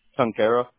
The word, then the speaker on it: Tunkara: Tunkara.